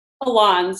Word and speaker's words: Alons: How would you say your last name? – Alons. So I think you said it right.